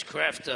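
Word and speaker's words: Kreeft: Peter Kreft, is that the right way to pronounce it? I've been calling the guy Kreef for years, but it's Kreft, I guess.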